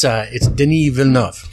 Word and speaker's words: Denis Villeneuve: Yes, it's Denis Villeneuve, and I heard him pronounce it 3 times last night.